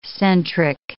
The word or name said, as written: centric